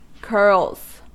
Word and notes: curls: American English

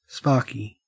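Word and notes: sparky: au Australian

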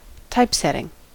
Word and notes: typesetting: American English

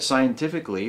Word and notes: scientifically: American English

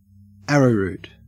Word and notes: arrowroot: Australian English

